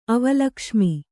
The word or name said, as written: ಅವಲಕ್ಷ್ಮಿ